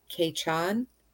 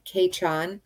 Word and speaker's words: Ceitean: And then also, if you look at it in Gaelic, it was saying that, like, in Scotland, I guess it's Scotch Gaelic, their word for May is Caitean, which it's spelled Ceitean, but it's pronounced Caitean.